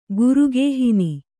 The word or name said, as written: ಗುರುಗೇಹಿನಿ